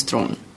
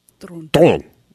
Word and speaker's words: Theron: I go by Charlize Theron, but it's the real pronunciation of my last name is Thron. – Thron.